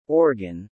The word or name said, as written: organ